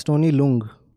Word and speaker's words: Tony Leung: Yeah, it's Tony Leung.